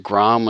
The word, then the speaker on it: Grom: I got to ask you something about this, because, you know, do you pronounce it Grom when it's G-R-O-M? Is that what it is?